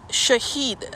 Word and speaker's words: Shahid: If you die, mostly it's used, it's not to be confused with a different word that's a religious term, and pronounced differently as Shaheed, S-H-E-E-D. What I'm talking of is Shaheed, S-H-A-H-I-D, mainly meaning to bear witness to God.